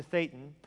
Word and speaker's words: Thetan: In the theology of Scientology, every human being in his true identity is a Thetan, pronounced Thetan. I realize what that rhymes with.